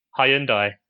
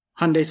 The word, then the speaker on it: Hyundai: Hyundai. – Okay. Over here, I've been told that it's supposed to be pronounced like the name of the day of the week. Hyundai Sunday.